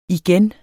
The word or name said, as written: igen